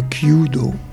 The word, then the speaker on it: Kyudo: Et donc, vous êtes spécialiste de l'arc japonais Kyudo, c'est ça, je prononce bien ? – Oui, c'est le Kyudo, c'est ça, oui.